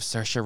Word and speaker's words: Saoirse: That's like me saying say Ronan instead of Saoirse Ronan for like half my life. – What? – Saoirse Ronan. – I used to call her Saoirse. – Saoirse? – Yeah.